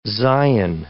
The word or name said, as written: Zion